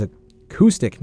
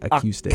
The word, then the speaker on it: acoustic: Yes, that is acoustic music, not acoustic. – Acoustic music. – So, yeah, mispronunciation there by me, but there was a lot of threes.